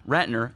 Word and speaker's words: Ratner: Ratner, am I saying that right? – Ratner.